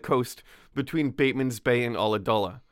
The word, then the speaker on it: Ulladulla: It's that YouTube, here's how to pronounce Ooladoola. I was wrong. It's Ulladulla.